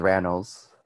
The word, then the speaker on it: Rannells: Is it Rennell or Rannell? – I always thought it was Rannells.